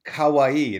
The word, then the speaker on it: Kawaii: Kawaii. It rhymes with Hawaii. – Kawaii.